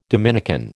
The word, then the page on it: Dominican: American English